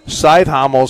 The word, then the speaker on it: Cajthaml: C-A-J-T-H-A-M-L. Scythe Hommel is how they pronounce it.